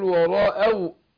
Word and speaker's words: ورأوا: ورأوا الواو مشددة، خدت بالك؟ فطبعا الحرف المشدد لازم يختلف في النطق عن مثيله اللي زيه يعني الغير مشدد. فقول ورأوا، ورأوا أنهم، ورأوا أنهم قد ضلوا.